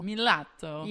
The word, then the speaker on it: Milazzo: Well, like in Italian, it's like Milazzo. – Milazzo. – Yeah. That was really good.